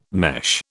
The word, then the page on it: mesh: British English